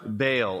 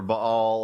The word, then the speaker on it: Baal: Baal or Baal or however you want to pronounce that. How do you guys say it here? Baal? Yeah, both. We say either way.